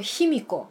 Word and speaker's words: Himiko: 卑弥呼，呃，他日文的名字发音叫做 Himiko，他是可以说是日本的第一个王。